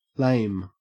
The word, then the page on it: lame: Australian English